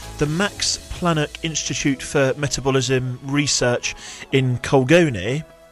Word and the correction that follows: Cologne: Is it Colgon or is it Colgone? – Oh, how do you spell it? – Colgone. Colgone, isn't it? – Cologne.